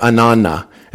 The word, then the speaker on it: Ananna: Ana, Ana, or Ananna is the way we actually pronounce it in Aramaic.